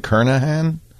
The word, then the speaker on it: Kernighan: Really pronounce Kernighan Kernahan? Wow. I've been saying it wrong my whole life.